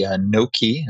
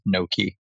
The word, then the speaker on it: Nokē: In addition to leading their corporate strategy, you were leading the Nokē, it's spelled N-O-K-E, business line.